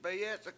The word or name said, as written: bayEc71k